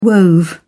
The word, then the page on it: wove: British English